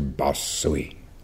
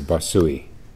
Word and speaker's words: Basui: You know, one of the hardest things about speaking Japanese for Westerners, I've heard, is that every syllable gets the same emphasis. There's no one that's accented over the other. So we tend to say Basui, Basui. But I remember Roshi Kapil always saying Basui, Basui. And I'm probably not even getting it right there.